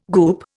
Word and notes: goop: Indian

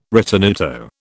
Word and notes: ritenuto: British English

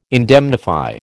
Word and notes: indemnify: American English

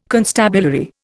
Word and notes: constabulary: Indian

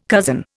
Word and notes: cozen: Indian

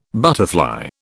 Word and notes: butterfly: British English

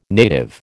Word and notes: native: American English

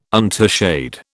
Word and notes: untouched: British English

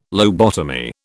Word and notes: lobotomy: British English

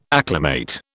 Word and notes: acclimate: British English

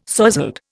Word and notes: sozzled: Indian

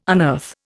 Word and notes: unearth: Indian

